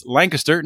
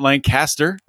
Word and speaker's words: Lancaster: That's Lancaster, not Lancaster, Pennsylvania, yeah, for right.